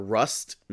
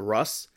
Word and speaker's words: Rust: We uh, I got his name wrong last week. It was Tyler Rust, not Russ.